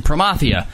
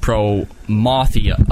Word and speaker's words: Promathia: That drives me nuts when you guys say Ergan, but nothing more than Promathia. Just because it has the word math in it doesn't mean you pronounce it like the subject math. It should be pronounced pro-mothia, not pro-mathia. If you guys could pronounce them right in future episodes, that, to me, would make a world of difference.